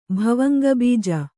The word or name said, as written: ಭವಂಗಬೀಜ